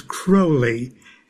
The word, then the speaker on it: Crowley: Well, the way Aleister Crowley pronounced it was Crowley, and he said it rhymes with holy.